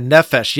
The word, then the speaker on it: Nephesh: Yeah, Nephesh. Yeah, let's dive in there because that's fantastic as well. Yeah. – Yeah, tell me about that. – So Nephesh, I actually did some reading because Nephesh is kind of an all-encompassing word.